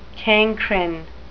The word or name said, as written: cancrine